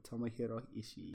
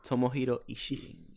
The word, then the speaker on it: Tomohiro Ishii: You said Tomohiro Ishii. Yeah. That's good. And you know what? You said it right because it has two I's at the end. That's how you technically say it.